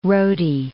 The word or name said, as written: roti